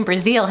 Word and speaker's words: Breazeale: Actually, my students call me Dr. B because that last name Breazeale has too many E's and A's in it.